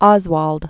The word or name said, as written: Lee Harvey Oswald